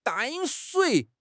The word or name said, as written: 淡水